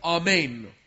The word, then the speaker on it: amen: And yet, also, sometimes in the Hebrew Bible, it appears in that original form as the amen. Amen. And that's how the Hebrews say it because of the long vowel there. Amen is how it's said.